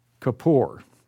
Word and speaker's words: Kippur: It's Kippur, K-I-P-P-U-R, pronounced Kippur.